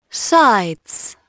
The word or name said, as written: sides